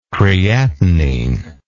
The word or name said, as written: creatinine